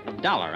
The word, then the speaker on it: Dollar: Dollar, huh? – Yeah, it rhymes with collar.